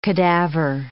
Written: cadaver

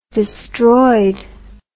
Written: destroyed

